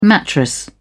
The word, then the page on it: mattress: British English